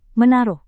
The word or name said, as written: menaruh